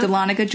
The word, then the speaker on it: Dahlonega: And then the last one is the Smith House in Dahlonega. Am I saying that right? – Yeah. – Dahlonega, Georgia.